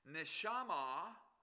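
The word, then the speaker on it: neshama (נשמה): The word breath is pronounced neshama in Hebrew. Neshama.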